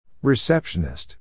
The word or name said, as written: receptionist